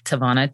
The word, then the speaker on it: TaVona: Well, the easiest way is to find me on my website, which is TaVona Denise. And my name is spelled TaVona, T-A-V as in Victor, O-N-A, Denise, D-E-N-I-S-E.